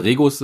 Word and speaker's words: Regus: Ja, da sind wir tatsächlich drin im Coworking Space, dann halt, wir sind bei Regis drinne dann halt, nicht? Ne? Oder Regus, äh, je nachdem, wie man es aussprechen möchte.